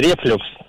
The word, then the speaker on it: рефлюкс: Согласно данным русских орфографических словарей, в данном слове ударение всегда ставится только на второй слог. Поэтому правильно говорить рефлюкс, уважаемый Антон.